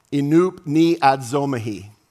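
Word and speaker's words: enupneadzomahi (ἐνυπνιάζομαι): The Greek word here is pronounced enupneadzomahi.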